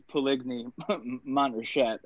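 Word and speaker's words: Puligny-Montrachet: I believe there's a G, though, in the name. So I don't know that you're pronouncing it correctly, but that's okay. – How would you say it? How would you say it? – I mean, it's clearly written as Puligny Montrachet.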